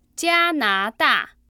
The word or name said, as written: jianada